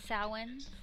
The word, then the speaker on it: Samhain: How do you say it correctly? – Samhain. It's a Celtic word.